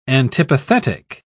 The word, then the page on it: antipathetic: British English